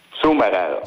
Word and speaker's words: Sommerer: Come si pronuncia esattamente? – Sumerer. – Ah, Sumerer.